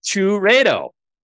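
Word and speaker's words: Chourio: And what I, Chureto. That's what he spelled out for me, Chureto.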